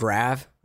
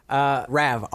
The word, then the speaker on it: Rav: Rav, R-A-V. That's my new real name. – Like the car, like the Rav4.